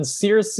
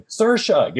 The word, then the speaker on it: Saoirse: And so he's like going through and saying, oh, so then Circe, and then you can hear the guy in the back of the podcast going, Saoirse.